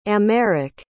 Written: aymeric

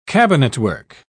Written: cabinetwork

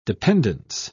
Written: dependence

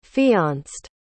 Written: fianced

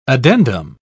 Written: addendum

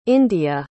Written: india

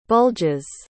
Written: bulges

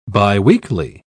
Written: biweekly

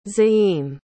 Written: zaim

